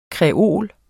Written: kreol

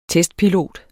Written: testpilot

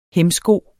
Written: hæmsko

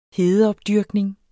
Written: hedeopdyrkning

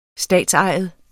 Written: statsejet

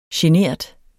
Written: genert

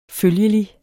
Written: følgelig

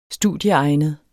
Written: studieegnet

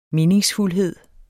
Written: meningsfuldhed

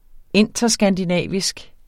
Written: interskandinavisk

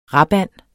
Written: rapand

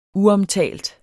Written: uomtalt

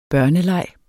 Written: børneleg

